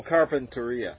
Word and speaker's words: Carpinteria: Oh, Carpinteria. – That's the Spanish. And the Spanish is penultimate syllable.